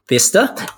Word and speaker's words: Bicester: But there were lots of tutus at Bicester Park Run. Vista, not Bicester. I know you love pronouncing English place names, so excuse the narrative through there. And thank you very much for the correction there, Phil.